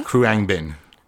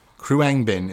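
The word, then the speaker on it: Khruangbin: It's actually, I found out the way to pronounce it. – Okay. – It's Kruang Bin. – Okay. – Kruang Bin is the name of the band.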